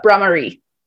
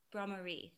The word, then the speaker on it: Bhramari: Will you please say it in Sanskrit? – Uh, Bhramari. – Bhramari. Thank you so much. – Yes. Um, I knew I was going to say it wrong, so I appreciate you so much for saying it correctly.